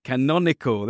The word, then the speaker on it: canonical: I'm actually going to quote you something I read where you said, scholars can help songwriters discover the intertextual and canonical. How do you say that word? – Canonical. Yeah.